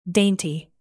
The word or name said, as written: dainty